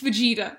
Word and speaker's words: Vegeta: It's Vegeta.